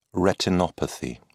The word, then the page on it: retinopathy: British English